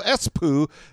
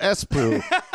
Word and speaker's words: Espoo: Thank you so much, president of the Finnish Knowledge Club, Knowledge Fight fan club of Espoo. The city is pronounced like Espo, not Espoo.